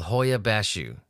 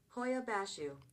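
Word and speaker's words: Hoia Baciu: We're going to go to Transylvania, to a place called Hoia Bashu, which that is how it's pronounced.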